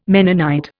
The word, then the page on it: Mennonite: Indian